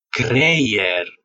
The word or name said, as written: creier